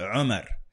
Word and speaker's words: Umar: And so, for example, my brother's name is Umar. – Yeah. – I pronounce it Umar. – Oh. – Umar. – I recall that. It's like there's a unique way you say Umar versus Umar. – Umar. – Yeah. – Right? The way everybody else Umar. Does it. Umar. – Yeah. – We don't pronounce it. We go Umar. And that's reflective of the Warsh okay.